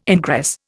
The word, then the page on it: ingress: Indian